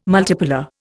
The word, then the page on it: multipolar: Indian